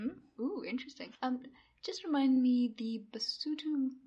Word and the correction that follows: Basotho: the Basotho uh, myth you did before was Tikane, the dragon-slaying princess, yeah?